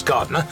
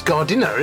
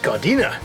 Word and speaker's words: Gardiner: Yeah, actually, I wonder if, because his name is spelled, often people say Boris Gardener, and really it's Gardiner, isn't it? Because Gardiner. – Gardiner. – Because it's got the I in it.